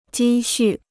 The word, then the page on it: 积蓄: jī xù